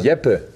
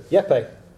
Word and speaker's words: Jeppe: Uh, Jep uh, Lawson Brock Jeppe. Anyway, Fire away. What I say doesn't matter. Yeppe. – Yeppe. – Right? Exactly. Perfect. Perfect pronunciation.